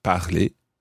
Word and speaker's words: parlé: So we combine j'ai, tu as, il a, and so on with the past participle of parler, which is parler. It sounds the same, but it's spelled P-A-R-L-E-A-C-U-T. So this now becomes I have spoken, j'ai parlé,